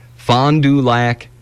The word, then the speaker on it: Fond du Lac: This dude throughout his life, since 1970-something, I think 72, he's a retired guy, Don Gorski from some place in Wisconsin, Fond du Lac, Wisconsin. It's spelled F-O-N-D-D-U-L-A-C.